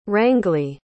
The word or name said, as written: Rangley